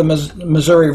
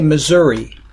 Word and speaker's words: Missouri: I say, because I'm south of the Missouri River, I say Missouri. If you live north of the river, you say Missouri. But either side of the river, in the summer, everybody pronounces it Misery. That's how we do that.